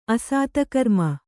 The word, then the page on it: ಅಸತ್ಕರ್ಮ: kannada